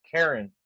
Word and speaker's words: Charon: Oh, but if I said that wrong, the ferryman was Karen. That was the actual name. It's spelled like Sharon, C-H-A-R-O-N, but it said Karen, which is really funny.